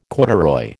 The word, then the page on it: corduroy: American English